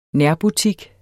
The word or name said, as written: nærbutik